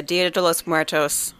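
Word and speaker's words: Día de los Muertos: So this episode comes out November 1st, which is smack dab in the middle of the Dia de los Muertos celebration period.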